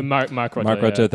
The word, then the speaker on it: Rutte: Rutte, I don't actually know how to say his name. Rutte, Mark Rutte,